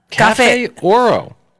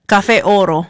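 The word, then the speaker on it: Café Oro: Cafe Oro. – Oh, my gosh, don't say it like that. Cafe Oro.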